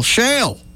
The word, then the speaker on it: shale: Not shell, shale. It's like shell pronounced in Alabama. Shale.